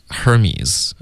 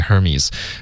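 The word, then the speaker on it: Hermès: 如果这是因为英文有这个字，它是发 Hermes，是一个对，希腊的一个神，对、哦、对，Hermes，可是应该不会有这么发这个品牌的，因为这个品牌有他自己发的发音，对、啊、发音他就不读，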